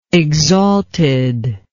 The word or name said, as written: exalted